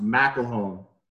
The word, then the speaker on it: McElhone: The last name is actually pronounced McElhone.